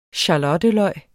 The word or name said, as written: skalotteløg